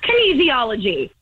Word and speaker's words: kinesiology: So, no, you stupid idiot. It's pronounced kinesiology.